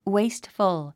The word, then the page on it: wasteful: wéistfəl; ウェイストフォゥ